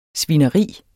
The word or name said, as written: svineri